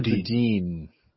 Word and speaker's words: Boudin: Chesser Boudin.